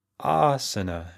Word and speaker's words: asana: And I'm still surprised how often I'll have students who come from other teacher trainings and they still pronounce it asana. And it doesn't help that there's that productivity app, Asana. That's how they pronounce it and they spell it the same, but they pronounce it asana. So you may have seen that app. It's a to-do manager, a business productivity app. I actually use it in my business, but we call it asana.